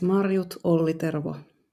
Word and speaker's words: Marjut Ollitervo: So, Marjut, please, for the record, just tell us the correct pronunciation of your name. – It's Marjut Ollitervo.